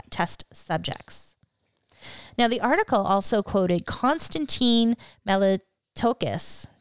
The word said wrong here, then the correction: Meletskos: Did I say it? – Meletskos. – Oh, sorry, I butchered that. Meletskos. – You were close.